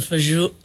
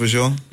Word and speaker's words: fagioli: I've had the pasta fagioli. How do you say it? – Pasta fagioli? – I almost got it right, didn't I? – And close.